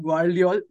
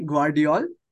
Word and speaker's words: Gvardiol: So we have Josko Gvardiol, excuse me, Gvardiol from RB Leipzig to yeah, it's a tough Manchester one to City.